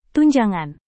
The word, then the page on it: tunjangan: Indonesian